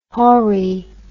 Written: hori

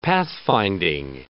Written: pathfinding

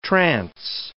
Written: trans

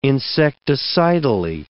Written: insecticidally

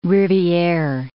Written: riviere

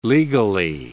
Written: legally

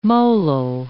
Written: molal

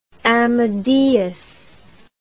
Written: amadeus

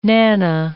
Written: nana

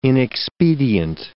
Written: inexpedient

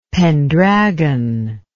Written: Pendragon

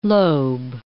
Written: lobe

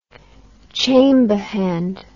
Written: chamberhand

